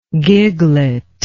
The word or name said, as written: giglet